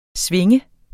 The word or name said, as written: sving